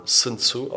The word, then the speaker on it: Sun Tzu: Ich habe gelesen, man soll seinen Namen Sun Tzu aussprechen, aber ähm, Mandarin ist, steht noch auf meiner Liste, das werde ich erst nächstes Jahr lernen.